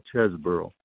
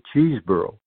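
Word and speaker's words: Chesbrough: As a kid, he was always called Chesborough, and that that has been changed by SABR to Cheeseborough. And I I maintain that the old pronunciation is correct. And uh, I there's only one e in there.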